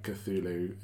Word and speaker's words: Cthulhu: Yeah, it's like I heard a couple of different ones, but I feel like Cthulhu is the geeky agreed upon pronunciation.